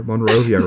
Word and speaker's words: Monrovia: What do we know about Liberia or Monrovia, Rudy? – Speaking of not that deep. I know that it rhymes with Zenobia or Genovia, whatever it was, from Princess Diaries, as I said already.